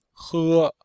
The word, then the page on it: he: he1